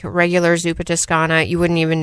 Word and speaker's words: Zuppa Toscana: The one that caught my eye was the Zuppa Toscana. Am I pronouncing that correct? – Yeah, Zuppa Toscana.